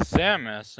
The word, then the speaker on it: Samus: I saw it pronounced as Samus, and I think. – Well, I think that's just the like Western pronunciation. – Okay, I thought that one. – You know, with stuff like in Japan and pretty much every Asian culture, and you know a lot of other ones. You know, the A is usually pronounced, you know, with the aw sound.